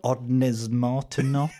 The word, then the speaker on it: Ondes Martenot: Um, is it an is it an Owen Owen Martin? – Ondes Martenot.